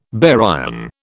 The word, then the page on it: baryon: British English